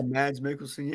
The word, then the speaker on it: Mads Mikkelsen: He's the director and starring Mads Mikkelsen. Am I saying that right? – Yeah, Mads Mikkelsen, yeah.